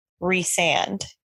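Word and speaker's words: Rhysand: In the audiobooks, she pronounces it Resand.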